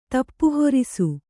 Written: ತಪ್ಪುಹೊರಿಸು

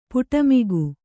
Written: ಪಡುಮಿಗ

